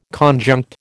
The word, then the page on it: conjunct: American English